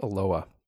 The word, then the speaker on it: Aloha: It's spelled A-L-O-H-A. It's actually pronounced Aloha. Don't ask me why. I don't know. But that's, you'd think Aloha because that's how it's spelled.